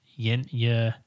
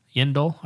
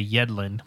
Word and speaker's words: Yendel: He's putting on his makeup, and the current commissioner is like Yendel. – Yendel. I keep wanting to say Yedlin, like DeAndre Yedlin, the defensive player for the U.S. men's soccer team. Yendel rhymes with Kindle.